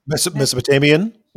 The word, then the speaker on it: Mesopotamian: Mesopotamian, how do you say that? Someone help me. – Mesopotamian? – Yes, thank you.